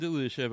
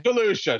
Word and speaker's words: DeLuca: That's Jason DeLuca, by the way. – DeLuca, excuse me. – No. It's spelled. You're reading it how it's written. I'm telling everyone else, not you.